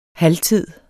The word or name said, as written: halvtid